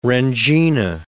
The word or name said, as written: rendzina